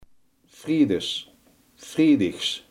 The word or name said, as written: vrijdags